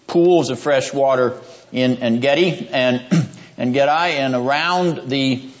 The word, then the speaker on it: En-gedi: Yes, En-gedi. I'll use the Hebrew pronunciation. It's a long I. En-gedi. Where we left David in chapter 23, we now begin with David in chapter 24.